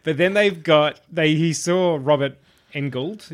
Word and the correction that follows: Englund: Is that how you say his name? – Englund, yeah. Englund. I think.